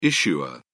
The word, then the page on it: issuer: British English